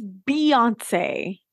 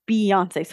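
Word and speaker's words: Beyoncé: Well, it's Beyonce. And yes, it's spelled B-E-Y, but it's Beyonce.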